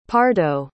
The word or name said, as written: pardo